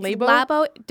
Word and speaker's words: Labo: Labo? – It's Labo. Labo. Don't make me say this. What Labo sounds like, because you know what it sounds like, and I don't want to say it. Labo.